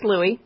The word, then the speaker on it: Louis: It's just Louis, because it's a French pronunciation.